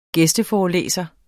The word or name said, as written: gæsteforelæser